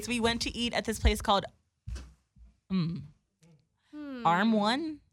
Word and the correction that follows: Erewhon: No, I'm saying it wrong. It's like some organic like grocery store / like eatery place or whatever. – Erewhon. – There we go. Oh, okay. I said it kind of right.